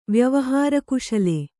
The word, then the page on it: ವ್ಯವಹಾರಕುಶಲ: vyavahāra kuśala